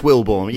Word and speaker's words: Wilbourn: Is it Wilbourn or Wilbourn? I get so nervous with people's names. I think I've said it wrong. – It's Wilbourn. You were close enough. I'll give you that. – Wilbourn. So, yeah. Like you're being born.